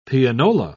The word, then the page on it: pianola: British English